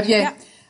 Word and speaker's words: Abyei: Abyei. Is that how you pronounce Abyei? – Yes, that's right. – Abyei, yeah. Burundi, and Uganda.